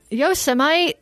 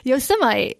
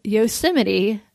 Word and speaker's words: Yosemite: Yosemite. Did you hear about that? – No. – Donald Trump pronounced the word Yosemite Yo Semite.